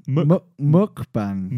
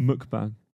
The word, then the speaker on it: mukbang: Mukbang. – Mukbang. – Mukbang.